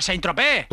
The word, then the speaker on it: Saint-Tropez: No se dice, la Z no se dice, ¿verdad? Se dice Saint-Tropez, no Saint-Tropez.